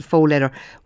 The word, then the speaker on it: faux leather: So you've got now, you've got stuff like this faux leather that I think that's the right way you pronounce it, faux leather.